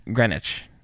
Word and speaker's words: Greenwich: And then finally, Thor and Jane arrive in Greenwich, which is where... – Greenwich. – Oh. – Say it like they do.